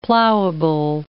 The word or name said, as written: plowable